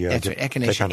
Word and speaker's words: echinacea: So let's talk a bit about echinacea before we echinacea.